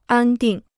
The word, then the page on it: 安定: ān dìng